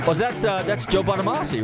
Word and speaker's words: Bonamassa: Hey, you're tuning into Nostalgic Radio and Cars, and let's play a little Joe Bonamassa.